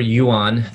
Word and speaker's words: Yuan: It's my full name, Christopher Yuan. That's spelled Y-U-A-N. That's actually the same. People say, you know, that's the Chinese dollar. So you can call me money.